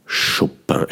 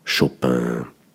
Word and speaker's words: Chopin: Chopin, ah, die, die, die, die Franzosen, im Französischen betont man ja immer das Ende des Wortes, nicht wie im Deutschen die Stammsilbe, die meistens am Anfang ist. Deshalb neigen wir dazu, Chopin zu sagen. Also wir, wir Schweizer sagen Chopin. Aber im Französischen äh, kommt die Betonung am Schluss, am Ende des Wortes. Deshalb sagen die Franzosen vielleicht Chopin.